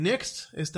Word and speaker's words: NeXT: En ese sentido, bueno, déjame, nada más te puntualizo que Next, esta empresa Next Computers, pues fue fundada, fue fundada en 1985 por Steve Jobs. Eh, su nombre como tal se pronuncia, se escriba next como siguiente.